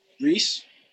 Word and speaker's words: Reese: But how do you pronounce it? – Reese. – Oh, okay. – Like Reese's Pieces?